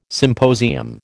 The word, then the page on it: symposium: American English